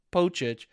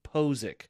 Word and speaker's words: Pocic: Okay, so his last name's spelled P-O-C-I-C, which a lot of people pronounce it Pochich. It's actually, for phonetic spelling, is P-O-H-Z-I-K, Ethan Posick.